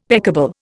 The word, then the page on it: peccable: Indian